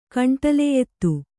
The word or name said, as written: ಕಂಟಲೆಯೆತ್ತು